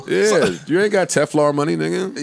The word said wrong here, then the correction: Telfar: Wait, am I saying it right? Is it Teflar? No, it's Telfar. – Telfar.